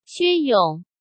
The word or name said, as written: Xue Yong